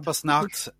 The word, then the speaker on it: Krampusnacht: Krampusnacht? Did I say it correctly? You've got some German blood. – Krampusnacht is correct. – Well, they kind of roll the R, which I'm not. I can't roll my R, so they, so it's more like the rolling R Krampus.